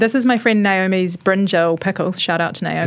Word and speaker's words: brinjal: Brinjal.